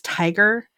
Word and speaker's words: TIGHAR: And there's apparently a aeronautical, I can't remember what it stands for, but their group, the group name is Tiger. It's, let's see if I can find it real quick. Let's see. And I believe it is T-I-G. – That's Tigger. – No, it's spelled funky. – Spelled like Tigger. What are they for? – They are, yeah. T-I-G-H-A-R.